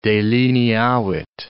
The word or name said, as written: delineavit